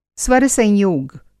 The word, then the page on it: स्वरसंयोग: Hindi